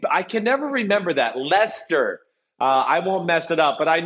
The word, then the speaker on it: Leicester: it's Leicester, not Leicester or whatever it is that you said.